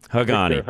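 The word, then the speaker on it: Haghani: It's H-A-G-H-A-N-I. How do I pronounce it, Victor? – Hagani.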